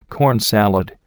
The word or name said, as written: corn salad